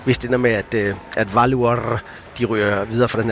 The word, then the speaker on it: Valur: Hvordan er det med, du udtaler det? – Valur. – Valur. Valur fra Reykjavik. Det er nemlig rigtigt.